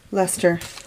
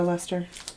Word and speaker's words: Leicester: Leicester. – There you go, Leicester.